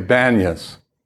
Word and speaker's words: Banias: Thus it's called Panias, but in Arabic they don't pronounce the P, so they say Banias, the B.